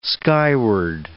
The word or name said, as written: skyward